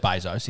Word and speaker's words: Bezos: Bezos, I think they pronounce it. – I believe it's Bezos. Yeah.